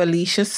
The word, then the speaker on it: Alicia: Listen now to Alicia's story.